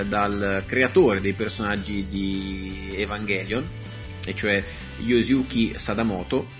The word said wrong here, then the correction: Yoshiyuki Sadamoto: anzi Yoshiyuki Sadamoto, ecco, la pronuncia giapponese non mi fa difetto a differenza di quella inglese.